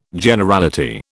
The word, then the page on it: Generality: British English